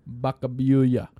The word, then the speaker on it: Vacaville: What we ended up doing was staying around in small town Vacaville, mm-hmm. – which should be pronounced Bacabuya, B-A, or whatever. It's look, that's Spanish for cow village.